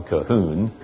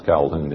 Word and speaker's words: Colquhoun: I'm rather pleased to know that one of those Marrow Men was a man named John Colquhoun, as people pronounce Calhoun in Scotland, who was minister at Leith and wrote a number of books defending Thomas Boston and the Marrow.